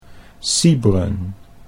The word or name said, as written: Siebren